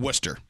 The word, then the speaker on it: Worcester: This is one of the few cases where we're actually retaining British English pronunciation. This sauce was first made in the city of Worcester, England. – Worcester.